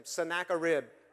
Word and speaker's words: Sennacherib: And they were led by a guy by the name of Sennacherib. I always called him Sennacherib. Snacking on a rib. That's how you remember how to say his name. Sennacherib.